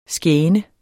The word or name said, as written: skæne